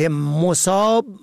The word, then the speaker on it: مصاب: مصاب به ضم اول به معنای مصیبت زده، سختی دیده، مصیبت رسیده. مصاب به فتح اول جمع مصب به معنای محل، مورد، موضع. مصاب به کسر اول به مفهوم سیراب، پر و فرد سیراب و پر شده از آب.